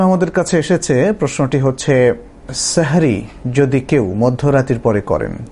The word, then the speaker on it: সাহুর: সাহরি মধ্যরাতের পরে করলে কোনো অসুবিধা নেই, তবে সাহরির ক্ষেত্রে, যেটাকে আরবিতে সাহুর বলা হয় মূলত, সাহার অথবা সাহুর, বাংলায় যদি আমরা সেহরি বলি, শব্দটার আরবি উচ্চারণ হচ্ছে সাহুর, এই সাহুর যদি কেউ মধ্যরাতের পরে তিনি নেন অর্থাৎ পানাহার করেন তাহলে কোনো অসুবিধা নেই, যদি তিনি দেরি করেন সেটাই হচ্ছে সুন্নাহ।